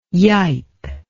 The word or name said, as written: yipe